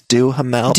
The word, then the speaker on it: Duhamel: That's how you say his name, right? – I think it's Duhamel. Duhamel.